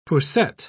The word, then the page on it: poussette: British English